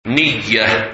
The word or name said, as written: Niyah